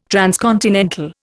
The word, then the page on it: transcontinental: Indian